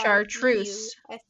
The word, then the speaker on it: chartreuse: Charoust. I still don't believe. What is it? – Do you know the color charoust? I may be saying that wrong. – Spell it? – C-H-A-R-T-R-U-S. – Chartreuse. – Chartreuse.